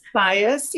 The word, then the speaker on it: Baez: Baez, yeah.